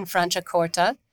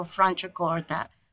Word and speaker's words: Franciacorta: With her brothers in charge of the wonderful Cantina Berlucchi in Franciacorta, where her father, Franco Ziliani, uh, got together with Guido Berlucchi, and the two of them invented the Franciacorta style of wine.